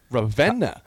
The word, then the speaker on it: Ravenna: But it's spelled R-A-V-E-N-N-A. There's no van in that. It's Venna. Ravenna.